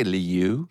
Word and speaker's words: lieu: Did you just say lieu? – He did. Beautiful pronunciation.